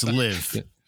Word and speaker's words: LIV: If you win that event in London on the LIV tour or whatever that is, I don't know, is that like 55? Is that a Roman numeral thing, or if I'm saying that right? – It's LIV. – LIV, okay, so it's this LIV tour.